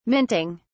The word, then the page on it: minting: British English